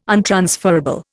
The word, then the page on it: untransferable: Indian